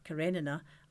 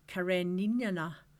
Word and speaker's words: Karenina: I don't remember my first line, but I remember I had to say the name Anna Karenina, pronounced Karenina, and I said Kareninana.